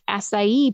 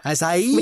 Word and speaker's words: acai: Organic acai powder. – Acai. – Which is acai, which is spelt A-C-A-I.